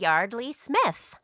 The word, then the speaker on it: Yeardley Smith: Jordan said Yardley Smith.